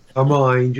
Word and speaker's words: Hermine: Hermine, just like M-I-N-E.